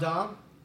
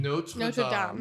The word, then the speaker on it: Notre Dame: That's not Dom? – Yeah, it's Notre Dame. – Of course it's Notre Dame. But I like the idea... – Wait, what's the correct pronunciation? – Notre Dame. – Notre Dame. – Oh, okay. – Smell your R's. It's French. Notre Dame.